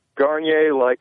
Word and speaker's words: Garnier: Sophie, S-O-P-H-I-E. – Got that. – And, Garnier, Garnier, like the shampoo. – Garnier Fructis. – Yes. – Hold on, looking at G-A-R-N-E-A. – N-I-E-R. G-A-R-N-I-E-R.